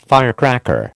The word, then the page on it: firecracker: American English